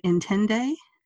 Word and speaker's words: Intinde: And I think, am I pronouncing it right, Intende? – That is perfect.